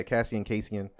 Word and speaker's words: casein: Yeah, casein.